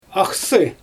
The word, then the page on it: axsI: Xyzyl